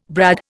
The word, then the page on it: brad: Indian